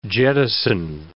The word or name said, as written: jettison